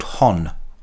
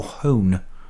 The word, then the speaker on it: Honne: An album by a band called Hon, or Hone. I say Hon. I think they say Hon. Anyway, it's spelled H-O-N-N-E.